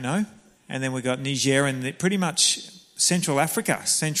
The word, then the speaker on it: Niger: For those who don't know, like I mentioned, they've been serving in a place called Nigeria. And we were chatting earlier about this place. I think I pronounced that right. Is that right? – Niger.